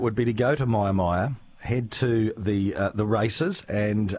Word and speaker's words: Mia Mia: The Myermyer races at Myermyer. Now, it's in northern Victoria. Check it out on the maps. Myermyer is spelled M-I-A-M-I-A.